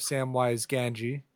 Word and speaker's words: Samwise Gamgee: Yes, the voice you heard doing a semi-approximation of Samwise Gamgee.